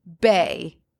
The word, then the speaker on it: beithe: So the Gaelic word for birch is pronounced bay, B-E-Y. – Bay. – Okay, but it is spelled B-E-I-T-H-E.